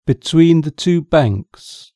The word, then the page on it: between the two banks: British English